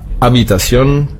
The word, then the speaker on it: Habitación: Zum Schluss hatten wir noch das H angesprochen, das war Habitación, stimmlos. Das H wird im Spanischen nicht mitgesprochen.